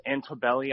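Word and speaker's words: Altobelli: We're going to go ahead and start right off with the tragic loss, losses in the Bryant family and the John Antelli. Please, help me out here. What's the college coach's name? – It's Antobelli, I believe it's pronounced.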